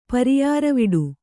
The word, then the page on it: ಪರಿಯಾರವಿಡು: kannada